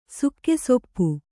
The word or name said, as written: ಸುಕ್ಕೆಸೊಪ್ಪು